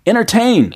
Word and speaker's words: entertain: 네, 빨리 발음할 때 yeah. entertain. entertain. 첫 번째 T 안 들리죠? – Yeah.